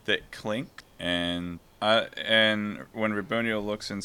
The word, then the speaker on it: Raboniel: Wait, how do you say her name? – You got it. – How do you say her name, Craig? Let us know. – I'm paranoid now. Is it Raboniel? – Yes. Got it. You're okay.